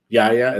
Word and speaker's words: Yahya: Yahya, is it Yahya? Okay, Yahya Abdul-Mateen II is going to be the lead in the series.